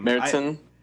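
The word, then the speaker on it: Märzen: So they released a Marzen, which was very extremely marginal, which is like a shame. – Meritzen. – I just really that's how you pronounce it.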